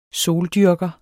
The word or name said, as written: SOLDYRKER